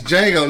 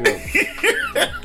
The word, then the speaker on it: Django: It's Django, nigga. The D is silent, motherfucker. Django.